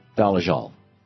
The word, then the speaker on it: Dolezal: Well, the Rachel Dolezal, I guess it's Dolezal. So I was pronouncing it with a hard Z yesterday. I guess it's a soft Z.